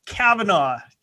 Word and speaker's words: Kavanaugh: there's no G. Kavanaugh. – Kavanaugh. Yes, there is a G. Silent G, but whatever.